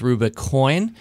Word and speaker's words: Rubicoin: So Rubicoin, which is spelled R-U-B-I, it's kind of like the Rubicon River crossing the Rubicon, but it's Rubicoin.